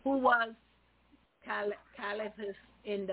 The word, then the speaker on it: Caiaphas: Caiaphas. There's no L in it. – Okay. That's the only way I know how to pronounce his name. He was... – You got it right, Caiaphas.